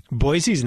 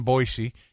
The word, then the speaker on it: Boise: It's Boise. Boise. Boise. Got to hit the oh, Z. Not the classy pronunci- pronunciation of Boise.